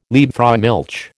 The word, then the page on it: Liebfraumilch: American English